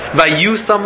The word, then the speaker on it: vayusam: And he sits down to eat a meal, and the Pasuk says, Vayusam lefanov lechol. It's a Korean exit. It's spelled yud yud, but it's pronounced vayusam. It's pronounced with a vav.